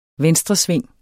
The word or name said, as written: VENSTRESVING